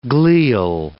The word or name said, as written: glial